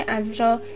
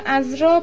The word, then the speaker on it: عذرا: کلمه عذرا یعنی باکره را با فتح عین و بر وزن همراه تلفظ کنیم و عذرا با ضم عین بر وزن صغرا و کبرا غلط است.